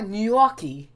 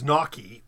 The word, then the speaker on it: gnocchi: But I was so proud of Fletcher for getting that GN correctly, because we hear a lot of times gnocchi, which is without, I mean, you don't pronounce the, people not pronouncing the G at all. And, I mean, it's a problem in English because it's pronounced like the GN is like lasagna.